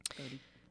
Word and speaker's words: Bodhi: And tell me why you chose his name, Bodie. – Yeah, so Bodie, it's spelled B-O-D-H-I. Bodie in Sanskrit means nirvana. The